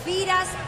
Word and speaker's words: Firas: ¿Ferras? Creo que es más fácil. – Se llama Feras. – Firas Al Burakian. – Pero se escribe Firas, sí. ¿No? – Sí. Firas.